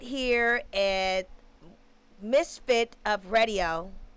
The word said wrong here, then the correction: Misfits Radio: Did I say that right? – I don't know. You got to ask them what they're doing. – Misfits Radio.